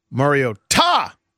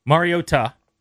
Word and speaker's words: Mariota: So here's what I said: Mariota, the broadcast team tonight. – Right, but why did you say that? – Because they're emphasizing the ta, which is the correct way to pronounce it.